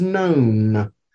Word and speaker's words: non: And the long O is like the sound of the O in holy. And the word I gave you was known. Known. – N-O-N? – You. I'm sorry? – N-O-N? – Mm-hmm.